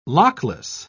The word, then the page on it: lockless: British English